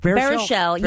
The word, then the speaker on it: Baruchel: Baruchel.